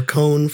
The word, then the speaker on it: Kon: GeekCon with a K, con. Just like con, you bloodsucker. Just like con or cone from Bleach that I directed. – Oh, yeah. – I directed that. You know, they're spelled the same way, KON. So, but it's con, GeekCon.